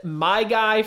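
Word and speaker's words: Myjai: You can pronounce it my guy for Maddie's guy out of Cincinnati, defensive end.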